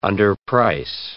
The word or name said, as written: underprice